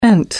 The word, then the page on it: ENT: American English